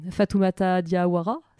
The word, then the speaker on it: Fatoumata Diawara: On écoutait à l'instant la chanteuse euh, Fatoumata Diawara, c'est comme ça qu'on prononce, c'est cool C'est ça Elle chante en quelle langue En bambara.